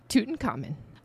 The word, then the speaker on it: Tutankhamun: Tutankhamun.